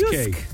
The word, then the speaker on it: JYSK: Are we saying it right? J-Y-S-K. – Yes, Yusk. Have faith in yourself, KC.